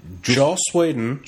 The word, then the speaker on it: Whedon: Joss Weirdo. Whedon. So, how do you say it? – Joss Whedon.